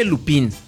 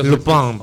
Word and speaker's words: Lupin: Se escribe Lupín. Lupín para los, pa los compas, ¿no? Para el compa que el escucha Lupin. Este programa. – Pero Lupin, para los que pronuncian.